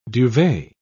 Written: duvet

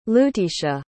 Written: lutisha

